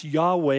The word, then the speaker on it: Yahweh: Interesting, it's the holy name of God, the tetragrammaton, the ha yah asher halayah, which we translate YHW, used to be transliterated Jehovah, but we think it's now pronounced Yahweh, and we're not sure because it's the unspeakable name.